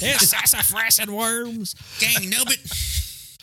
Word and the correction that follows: dang nabbit: It's Dang Nabbit, not Gang Nubbit.